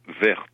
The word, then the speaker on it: Wertz: Il s'agit de Julia Wertz. Ça se prononce avec un V, mais ça s'écrit W-E-R-T-Z.